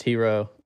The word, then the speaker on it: Hxro: For this, is it pronounced Hero? Am I pronouncing it right? – Yeah, it's pronounced Hero. – H-X-R-O.